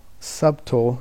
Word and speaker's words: subtle: Subtle is the way it kind of reads, but I think subtle is the way you pronounce it.